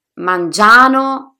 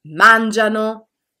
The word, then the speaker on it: mangiano: La pronuncia corretta, infatti, sarebbe mangiano, lavorano e camminano Riuscite a sentire la differenza? Mangiano, lavorano, camminano sono sbagliati, mangiano, lavorano, camminano sono corretti. Quindi ricordate ragazzi, al presente indicativo terza persona plurale l'accento non cade mai sulla desinenza.